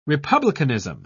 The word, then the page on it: republicanism: British English